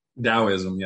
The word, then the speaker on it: Taoism: Taoism, yeah.